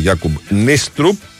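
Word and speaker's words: Jakob Neestrup: Διότι είπαμε τώρα από το που ολοκληρώθηκε η πρόσληψη του Γιάκουμπ Νίστρουπ, όπως τουλάχιστον μας ενημέρωσε η ΠΑΕ Παναθηναϊκός, ότι προφέρεται το όνομα του Δανού.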